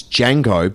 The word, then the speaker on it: Django: It's Django. But the D is silent.